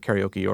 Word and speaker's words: karaoke: I mean, but he doesn't even own the rights to the word karaoke, or karaoke, as it's pronounced in Japan.